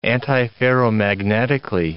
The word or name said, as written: antiferromagnetically